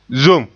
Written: zom

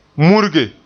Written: murige

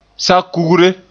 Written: sa-kugre

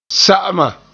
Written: sa'ama

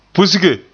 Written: pusge

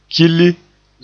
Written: kili

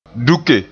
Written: duke

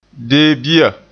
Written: deebia

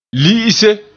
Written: li'ise

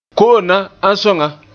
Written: na

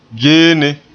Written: geene

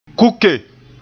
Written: kuke

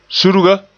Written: surga

